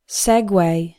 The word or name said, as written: segue